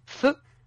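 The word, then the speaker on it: phở: feu, donc il s'écrit P-H-O, mais c'est qui se prononce feu, qui a ouvert et qui s'est appelé Feu King.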